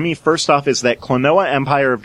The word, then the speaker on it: Klonoa: And for Wii U virtual console is Klonoa, Empire of Dreams. Am I saying that wrong? – No, that's Klonoa is correct. – It's not like quinoa, where you have to like pronounce it right? – No, Klonoa is not like quinoa.